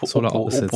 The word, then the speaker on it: Solar Opposites: Ja, Op Solar Opposites. Opposites. So spricht man das aus.